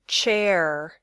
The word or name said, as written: chair